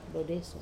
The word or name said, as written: dodeso